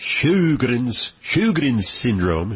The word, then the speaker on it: Sjögren's syndrome: Okay, so here's part of the problem, is that Sjögren's syndrome, and this is kind of a weird spelling, it's S-J-O with a little double dot over the O, so Sjögren's syndrome, it's like a Swedish word, the J is silent, and it's pronounced as an H.